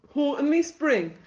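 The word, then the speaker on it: Houghton-le-Spring: Well, I'm from in between Sunderland and Durham, in a little town called Houghtonley Spring. – Houghtonley Spring, you say? So, I'm frequently told that I can't pronounce Houghtonley Spring. – Houghton. You did well there, to be fair.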